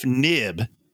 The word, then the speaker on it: Knibb: For my third pick, I'm going to go to Joseph Knibb, spelled K-N-I-B-B, high school. The K is silent.